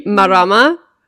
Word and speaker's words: Marama: Okay, Marama, which is a Maori gothic revenge horror film that came out, I think at like Sundance last year, but is now. M-A-R-A-M-A.